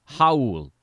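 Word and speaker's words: Raúl: By the way, it is pronounced Raul, like an H.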